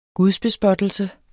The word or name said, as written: gudsbespottelse